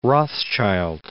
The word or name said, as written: Rothschild